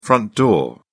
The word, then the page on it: front door: British English